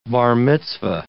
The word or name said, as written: bar mitzvah